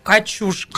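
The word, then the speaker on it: Качушкин: пишут слушатели и зрители, ударение на «у». Ну, хотя бы это выяснили. – Качушки.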